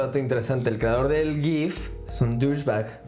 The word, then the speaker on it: GIF: No, no, no, no, Dato interesante: el creador de los GIFs insiste que se pronuncia GIF Aún, aún que sabe que a todo el internet le cae bien gordo que le digas GIF.